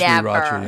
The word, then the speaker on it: sriracha: Also, I hate the people that go, it's sriracha. – I'll never. – Like, ah, stop it with the R. I hate that. – I would never. – It is sriracha. Yeah. It is spelled, but S-R. – Yeah, but this one is like S-I-R-A-C-H-A.